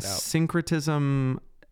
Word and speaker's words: syncretism: Yeah, syncretism, think synonym. That's syncretism is spelled S-Y-N, like synonym. So it comes from that same root.